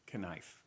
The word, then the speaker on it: knife: I was just trying to think of another silent letter word, but I can't. Oh, knife.